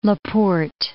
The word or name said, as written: La Porte